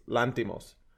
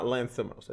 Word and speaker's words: Lanthimos: But but I do know that the H is not pronounced. It's Lanthimos. It's not Lanthimos.